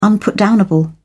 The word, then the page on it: unputdownable: British English